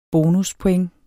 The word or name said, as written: bonuspoint